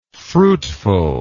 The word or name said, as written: fruitfulness